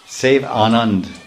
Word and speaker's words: Sevanand: He was always relishing Sevanand, Sevanand, Sevanand. We say Ananda, but the A is left off in general pronunciation.